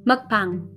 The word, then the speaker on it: mukbang: Is that how you say it in Korean? Mm. Mukbang? Mukbang. Mukbang. Yes, there we go.